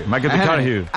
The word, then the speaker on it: McConaughey: Que hay que ir corrigiendo la pronunciación. – McConaughey. – McConaughey.